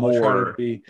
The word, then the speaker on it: Maury: So Maury. More, not Mar.